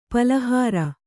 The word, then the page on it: ಪಳಹರ: paḷahara